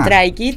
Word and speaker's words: Stray Kids: Bueno, nada, y ahora se nos vienen dos recitales importantísimos en la cultura, que tenemos uno de Stray Kids, que lo pronuncie bien. – Ajá, Stray Kids.